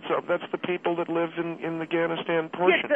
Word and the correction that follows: Afghanistan: Yeah, Afghanistan, not Ghanistanis.